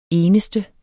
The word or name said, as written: eneste